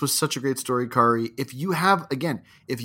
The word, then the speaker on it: Kari: And again, we'll put the pronunciations, the correct pronunciation is Kari, so kindly put in the descriptions of these of this episode.